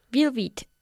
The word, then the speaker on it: velvet: Velvet. – Velvet, con V's. – Velvet. – Velvet. ¿cómo pronunciamos la V en inglés? – Colocamos el labio inferior justo detrás de los dientes superiores.